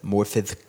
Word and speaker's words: Morfydd: Morfydd Clark.